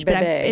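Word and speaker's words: bébé: And with that, there is a book called Bringing Up Bebé. Am I saying that correctly? I took Spanish. – Bebé.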